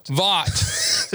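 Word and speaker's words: Vought: So anyway, episode one, we open with a working lunch meeting at Voight. CEO Stan Edwards. – I think Vaught is how they pronounce it. – Yeah. – It's like Voight.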